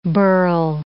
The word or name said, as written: birl